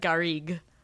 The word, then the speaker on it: garrigue: Garrigue. – Is that what it is? Garrigue? – Did I pronounce it wrong? I always said garrigue. – Garrigue, yeah.